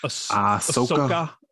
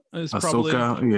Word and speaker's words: Ahsoka: What is it, like Osaka? – Ahsoka? Ahsoka, yeah.